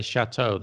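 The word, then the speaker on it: châteaux: I mean, in the French, you'd put an X afterwards, but you still say chateau. The pronunciation wouldn't change. And in English, you might say chateaus, two chateaus.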